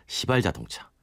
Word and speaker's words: 시발자동차: 이름하여 시발자동차. – 정말요? – 처음 시 출발할 때 발자 써서. – 발음을 잘해야겠네요. – 그렇죠. – 예. – 제가 쌍시옷은 안 알았습니다.